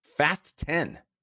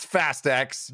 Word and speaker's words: Fast X: the pronunciation of this film is intended to be Fast 10. – Fast 10. – Oh, all right. – It is not Fast X.